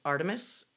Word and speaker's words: Artemis: Artemis. A-R-T-E-M-I-S.